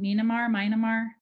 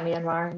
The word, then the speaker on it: Myanmar: I mean, you think you'll go back to, I say it wrong, Myanmar? – Yeah, Myanmar.